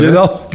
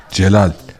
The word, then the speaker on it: Celal: Gelal. Gelal var. – Gelal. – Tabii istersen kuryeyle de gönderiyoruz. – Gelal değil mi? – Gelal. Al git. – Sahibi kim Gelal'ın? Celal. – G yazılıyor, C okunuyor.